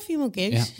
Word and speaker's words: female gaze: Of de female... Zeg ik dat goed? – Ja, female gaze.